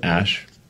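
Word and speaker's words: Æ: Um, so, I mean, it's just X, the letter X, and then the AE is like pronounced ash.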